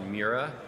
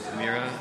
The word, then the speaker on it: Miura: It's called Mura, M-I-U-R-A. – I never know how to say it. I've never really said it out loud. – Mura.